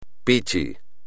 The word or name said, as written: pichi